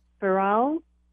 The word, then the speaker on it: Firaol: F-I-R-A-O-L. Farrell. – Okay. All right. So let's say we'll call him Sir. S-I-R.